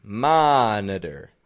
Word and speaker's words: monitor: How about monitor? Monitor. Now in here we're seeing three levels of syllable stress. Stressed ma. And then the N-I, we're giving a N with a schwa. Man. And then we're using some secondary stress on the der. On the flap T with the R.